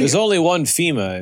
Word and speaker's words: Fhima: It's spelled with an H, right? FHIMA. – Yeah. You got it.